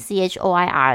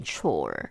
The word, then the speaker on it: choir: 只是说，如果看到这个拼音 c h o i r 的时候，我们按照正常的自然发音的逻辑，就会想要念 chore，可是它其实正确念法叫 choir。